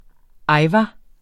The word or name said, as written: ajvar